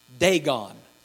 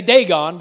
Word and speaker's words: Dagon: And it says that they took the Ark of God and took it into the house of, we pronounce it, Dagon. Now, I will probably continue to pronounce it Dagon, even though that's not how it's pronounced in the Hebrew. In the Hebrew, it's pronounced Dagon. Google it when you get home, or just Google it.